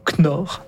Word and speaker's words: Knorr: Alors, je suis pas exactement sûr comment on prononce K-N-O-R-R, mais on va dire Knorr. Pas de K.